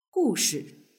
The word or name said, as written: hushi